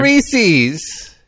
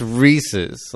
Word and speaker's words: Reese's: Not Reese's, just because it rhymes with pieces. It's Reese's, like his name's Reese.